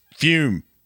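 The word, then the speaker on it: Füm: We're talking about our sponsor, Fume, pronounced Fume. F-U-M. It's got a little wiggly thing above the U.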